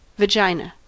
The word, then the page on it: vagina: American English